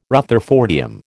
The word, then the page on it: rutherfordium: American English